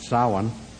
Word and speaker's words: Samhain: So Samhain, which is spelled Samhain, but pronounced Samhain, was to the Celts something like Rosh Hashanah is to the Jews.